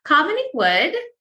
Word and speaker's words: Kamini Wood: I'm your host, and today I have a wonderful guest, Kamini Wood.